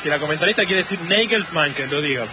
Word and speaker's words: Nagelsmann: Claro, es Nagelsmann.